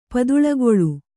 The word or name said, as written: ಪದುಳಗೊಳು